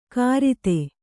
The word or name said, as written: ಕಾರಿತೆ